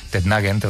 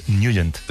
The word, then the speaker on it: Nugent: Hombre, siempre lo hemos llamado en el barrio Tednagent o Tednagent. – Claro, ¿verdad? Yo era de Tednagent. – Pero la pronunciación correcta es Nugent. – Te fuiste a Washington y descubriste que era Nugent.